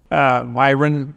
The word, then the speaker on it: Myron: Myron Dorn, M-Y-R-O-N and D-O-R-N.